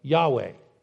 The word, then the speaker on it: Yahweh: When you hear the word tetragrammatron, it means the four-letter name of God, YHWH, or what we normally pronounce as Yahweh, right?